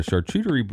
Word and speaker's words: charcuterie: always a good snack table and a charcuterie charcuterie. I don't say that. – You said it right the first time.